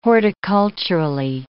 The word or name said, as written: horticulturally